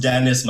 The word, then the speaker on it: Denis: It's pronounced Dennis. My mom spelt it the French way, pronounces it anglicized for whatever reason.